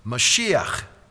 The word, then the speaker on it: Mashiach: It's pronounced in Hebrew, Mashiach.